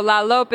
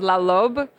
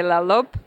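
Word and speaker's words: Lalop: So La Lope is how you say the name of the tree in South Sudan. In Arabic, it's La Lobe, but in South Sudan, they say La Lope.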